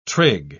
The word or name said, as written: trig